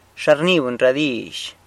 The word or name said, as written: xa rni buny ra dizh